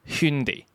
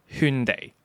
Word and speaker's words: Hyundai: 嗱，我想讲咧，首先我想 point 一个点系喺佢条片度咧，所谓正确嘅读音都有两个嘅，佢哋自己都搞唔清楚正确读音系咩噶，因为片尾嘅时候咧，有个 VO 读咗读做 Hyundai，跟住但喺條片入面個好靚嘅女士走出嚟更正大家讀音嗰個讀音咧，係讀圈地，圈地同圈地。